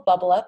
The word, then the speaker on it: Bublup: The company is called Bubble Up. It's spelled BUBLUP.